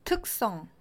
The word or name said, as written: 특성